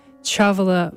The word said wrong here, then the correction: Chavela: I actually think her name is pronounced Chavela. I keep doing that wrong.